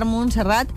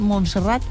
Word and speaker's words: Montserrat: ¿Montserrat? – Sí, Montserrat. – Montserrat, no lo he dicho bien. Montserrat. Sí, sí. – Montserrat.